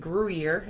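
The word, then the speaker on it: Gruyère: But I put a sweet cheese on there, and then I get your nutty cheese from, I believe, Gruyere is how it's said.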